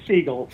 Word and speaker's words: Segal: Well, if it was Segal, you said it right, but it's Segal.